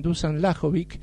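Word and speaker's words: Dušan Lajović: En el ATP 1000 de Cincinnati se quedó rápidamente sin argentinos, perdió Francisco Cerúndolo, perdió 6-3 y 6-4 ante el serbio Dusan Lajovic, ¿sí? Ahí lo dije bien, Dusan Lajovic.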